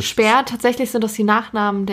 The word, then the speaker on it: Speer: Aber spricht man das auch Speer aus oder ist es auch Spiel? – Nee, nee, das ist Speer, das sind Österreicher.